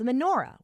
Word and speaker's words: menorah: First, the song talks about the menorah, which, as we learned yesterday, holds the candles that families light in Thanksgiving. Menorah rhymes with hora, which is a really fun dance often done at weddings and festivals.